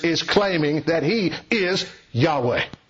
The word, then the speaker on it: Yahweh: which is pronounced Yahweh, the covenantal name of Creator God.